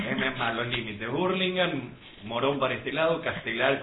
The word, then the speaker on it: Hurlingham: Ellos están en zona oeste, viven en Hurlingham, Hurlingham.